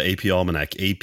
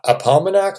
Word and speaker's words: AP Almanac: Is it Apalmanac? I'm like, no, AP Almanac.